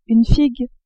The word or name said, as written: figue